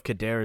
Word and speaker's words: Khadair: And in all honesty, I don't know if Khadair is the way that you pronounce that. I don't know what the correct pronunciation of it is.